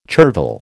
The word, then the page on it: chervil: American English